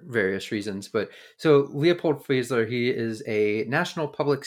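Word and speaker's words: Fieseler: It's F I E S E L E R is what I have it that, That would be Fiesler if we're meant to do a German pronunciation of that, which I assumed we are.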